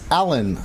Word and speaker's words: Alain: I think it's pronounced Alan. I think Alan? It's just Alan. – Is it just a funny spelling of Alan?